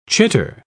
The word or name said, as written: chitter